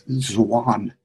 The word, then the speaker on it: Zwan: It's Zwan. ZW, it's pronounced like Swan, only Zwan.